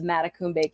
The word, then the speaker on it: Matacumbe: And you pronounced Matacumbe Key properly. – So glad. – A lot of people stumble on that one.